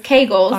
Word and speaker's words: Kegels: But it's Kegels. – I bet. Okay. And, Kegels. I've been saying it wrong too.